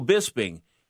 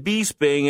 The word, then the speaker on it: Bisping: And my favorite guy, or one of them, Michael Bisping. Get his name right. Don't say it Bisping, anything like that, because he'll take you to task on it.